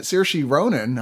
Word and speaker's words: Saoirse: This film, which has a terrific actress in it, Saoirse Ronan, I think, who is wonderful.